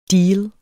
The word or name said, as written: deal